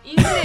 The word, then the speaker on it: ingle: Ingle es como inglés, pero le falta la S, ¿no? – Ingle, pero tiene el acento en la I, en la I primera.